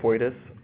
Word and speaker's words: FOITIS: We call it FOITIS.